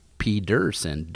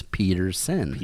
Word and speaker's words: Pedersen: But it's pronounced Pedersen, with a D, not Peterson or Peterson. It's Pedersen.